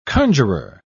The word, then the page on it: conjuror: British English